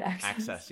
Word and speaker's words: AXS: Oh, is that how you say it? – AXS. Yeah. – I'm like, AXS? AXS. Whoopsie.